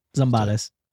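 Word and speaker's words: Zambales: So on the first night in Zimbali, am I saying that right? – Zimbales. – Zimbales.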